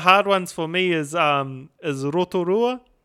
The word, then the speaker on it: Rotorua: Rotorua is just kind of like ingrained in the brain, but Rotorua, you know, Rotorua is the correct pronunciation.